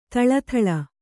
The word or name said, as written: ಥಳಥಳ